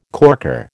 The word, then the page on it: corker: American English